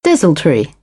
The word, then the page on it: desultory: British English; ə